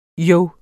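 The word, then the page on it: jo: Danish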